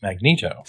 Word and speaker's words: Magneto: Magneto or Magneto? – It's Magneto.